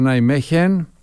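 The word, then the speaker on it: Nijmegen: Αυτός ήταν ο Γιάννης Ράνιος που μας μετέφερε το τι είδε στην Ολλανδία, στον Νάιμέχεν, όπως προφέρεται.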